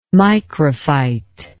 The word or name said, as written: microphyte